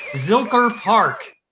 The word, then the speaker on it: Zilker Park: Zilker Park. Have you heard of this? – I have. We have been there many times.